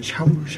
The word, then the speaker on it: Saul: 헝가리 발음으로는 샤울 샤울 샤울.